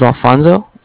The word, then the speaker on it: Alfonso: My name is Alfonso. – Uh-huh. – A-L-F-O-N-S-O.